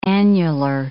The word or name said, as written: annular